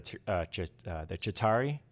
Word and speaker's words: Chitauri: which are the Chitauri.